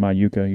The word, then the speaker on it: Iuka: Yeah, I don't even remember how you said it, but it's I like your I, a long U, and then ka, Iuka.